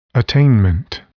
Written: attainment

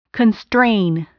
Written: constrain

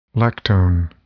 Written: lactone

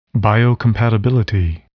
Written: biocompatibility

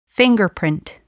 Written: fingerprint

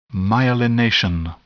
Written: myelination

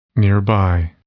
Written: nearby

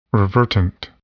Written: revertant

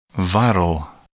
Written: viral

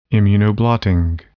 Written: immunoblotting

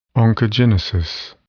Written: oncogenesis

